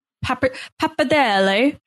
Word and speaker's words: pappardelle: And Joe made brisket pappardelle with harissa cabbage and corn.